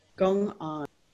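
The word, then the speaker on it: gong'an: G-O-N-G apostrophe A-N. How's that pronounced? – Gong-on.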